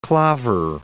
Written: Peter Claver

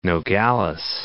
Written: Nogales

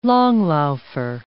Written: langlaufer

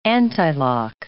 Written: antilock

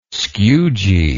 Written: skewgee

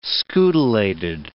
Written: scutellated

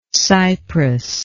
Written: cypress

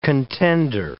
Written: contender

